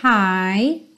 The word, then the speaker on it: high: So I guess I'll be hunting high and low. Ha! Lembrando, esse hi tem a mesma pronúncia do hi, hello, how are you doing? Ok? Hi. Então esse GH você não precisa se preocupar com ele.